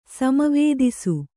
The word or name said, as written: ಸಮವೇದಿಸು